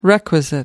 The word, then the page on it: requisite: American English